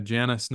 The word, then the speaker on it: Janus: That's right, yeah, Janus, not the English Janus motorcycles.